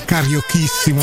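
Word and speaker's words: carioquíssimo: scritto col, con la Q e la U e la I, che però si pronuncia cariochissimo, perché gli abitanti di Rio de Janeiro sono i cariocan.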